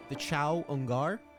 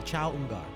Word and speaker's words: Chaungar: They're called the Chao Ungar. And they're passive. It's spelled Chaungar. The Chao Ungar.